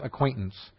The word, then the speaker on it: acquaintance: I mispronounced acquaintance. Acquaintance. I added a syllable.